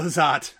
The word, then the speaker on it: Zott: Yes, but Paolo Zott is his name, rhymes with hot.